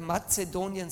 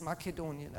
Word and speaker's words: Makedonien: Oder Mazedonien sagen wir auf Deutsch, aber auf Griechisch heißt es Makedonien. Also wenn wir Griechisch das aussprechen, Makedonisch und sonst Mazedonisch.